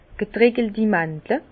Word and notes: getreckelti Màndle: Bas Rhin; Herrlisheim

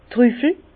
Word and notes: d'r Trüffel: Bas Rhin; Herrlisheim

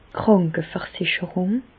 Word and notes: d' Krànkeversicherùng: Bas-Rhin; Reichshoffen

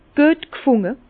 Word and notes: güet g'funde: Bas-Rhin; Herrlisheim

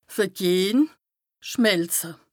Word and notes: vergehn, schmelze: Bas-Rhin; Strasbourg

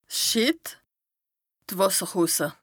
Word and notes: 's Schìtt, d' Wàsserhosse: Bas-Rhin; Strasbourg

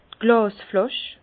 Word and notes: d' Glàsflàsch: Bas-Rhin; Herrlisheim

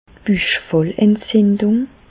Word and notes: d'r Büchfallentzindùng: Alsatian; Bas-Rhin; Reichshoffen

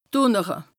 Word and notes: dùnnere: Bas-Rhin; Strasbourg

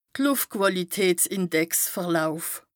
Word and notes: de Lùftquàlitätsindex-Verlauf: Bas Rhin

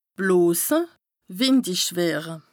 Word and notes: blose, wìndich wëre: Bas-Rhin; Strasbourg

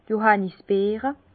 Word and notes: d' Johànnisbeere: pl.; Bas Rhin; Herrlisheim

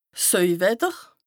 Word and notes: 's Söiwetter: Bas-Rhin; Strasbourg

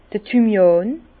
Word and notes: d'r Thymian: Bas Rhin; Herrlisheim

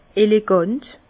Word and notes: elegant: Bas-Rhin; Herrlisheim

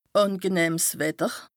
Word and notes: àngenëhms Wetter: Bas Rhin; Strasbourg

